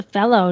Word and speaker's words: felloe: Why do they call it the fellow? Did you find out that? – Because they didn't want to insult ladies, so they insulted men instead. – Well, and it is spelled differently. It's F-E-L-L-O-E.